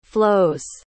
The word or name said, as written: flos